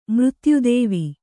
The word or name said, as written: ಮೃತ್ಯುದೇವಿ